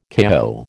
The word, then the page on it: KO: American English